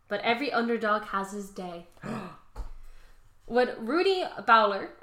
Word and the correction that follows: Baylor: It's Taylor, but B instead of a T. – Baylor? – Rudy Baylor?